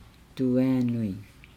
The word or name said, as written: duaa nui